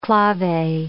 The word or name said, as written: clave